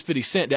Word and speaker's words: 50 Cent: When 50 Cent, I should say this correctly, he's not 50 Cents, he is 50 Cent.